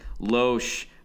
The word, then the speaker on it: Loesch: It says Loesch, long O rhymes with the Kosh in kosher, or Kosh in like the COC in precocious.